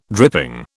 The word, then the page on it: dripping: British English